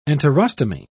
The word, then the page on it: enterostomy: British English